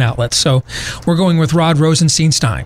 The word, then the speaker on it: Rosenstein: Rod Rosenstein. – Oh, it's Stein! I said it's Steen over and over again in the montage.